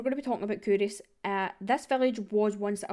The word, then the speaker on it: Culross: If you look it up, it will, it's spelled C U L R O S S, so it looks like it's pronounced Culross, but it's actually Kouris.